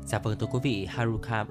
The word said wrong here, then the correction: Haruki Murakami: à, Haruki Murakami là tiểu thuyết gia có sách bán chạy nhất Nhật Bản đương thời.